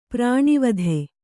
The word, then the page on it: ಪ್ರಾಣಾವಧಿ: prāṇāvadhi